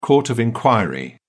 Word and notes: court of inquiry: British English

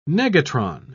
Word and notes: negatron: British English

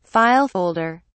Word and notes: file folder: British English

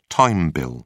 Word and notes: time bill: British English

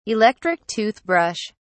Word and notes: electric toothbrush: British English

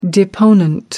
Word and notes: deponent: American English

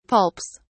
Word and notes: pulps: British English

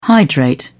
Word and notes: hydrate: British English

